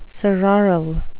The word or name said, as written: sororal